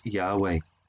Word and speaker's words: Yahweh: It's why in our English Bibles in the Old Testament we read of God as the Lord with a capital L-O-R-D. Notice that next time you read, that's where the original word was Yahweh, the personal name of God, Jehovah, as it used to be expressed in English, but probably better pronounced Yahweh.